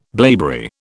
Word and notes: blaeberry: British English

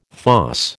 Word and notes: fosse: American English

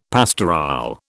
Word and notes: pastorale: British English

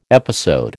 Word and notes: episode: American English

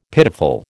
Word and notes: pitiful: American English

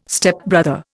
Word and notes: Stepbrother: Indian

